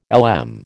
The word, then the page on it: LM: American English